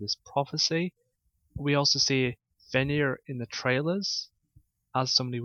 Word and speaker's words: Fenrir: But a lot of wolf images in Viking mythology is Fenrir. I probably pronounced the name wrong. – Fenrir.